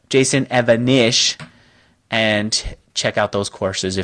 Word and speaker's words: Evanish: Oh, did I say his name wrong? And I really want to work with the guy even more. Evanesh. I say Evanosh. You're right. It's spelled Evanesh. Jason Evanesh. E-V-A-N-I-S-H.